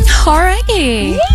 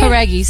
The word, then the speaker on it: Jauregui: Com vocês, Lauren Jauregui. Did I pronounce it right? – Jauregui, sim. Jauregui.